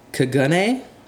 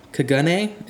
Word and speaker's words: Kagune: According to the phonetic way to say it, it's Kagune. Kagune. It looks like it's spelled Kaganer in like American, but it's yeah.